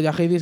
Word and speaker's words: Jaheiddy: Yahedi, pero Yahedi se escribe J-A-H-E-I-D-D-Y.